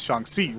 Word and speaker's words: Shang-Chi: And he says Shang-Chi, which is how you would pronounce it in Chinese.